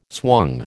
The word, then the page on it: swung: American English